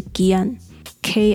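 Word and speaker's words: Kian (기안): 呃，如果说是韩文发音的话，大家应该比较熟悉的是 Kian，K I A N。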